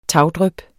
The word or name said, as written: tagdryp